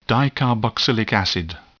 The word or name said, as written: dicarboxylic acid